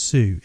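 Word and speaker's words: Tsu: So it's T-S-U, pronounced Sue.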